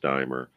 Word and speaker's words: Deimer: Well, it's, it's Kurt K U R T Deimer D E I M E R pronounced Dimer.